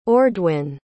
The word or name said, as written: ordwin